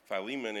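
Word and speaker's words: Filemón: And the guy in the wheelchair, his name is Philemon. So it's just like Philemon in your Bible, except it's spelled with an F, but Philemon.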